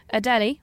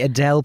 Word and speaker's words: Adélie: I'm going to talk about the Adélie penguin. – Sorry? – Adélie. – The Adèle penguin. – It's spelled A-D-E with an apostrophe L-I-E. – Adélie. Adélie. All I hear is Adèle.